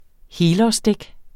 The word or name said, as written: helårsdæk